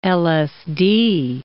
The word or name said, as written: LSD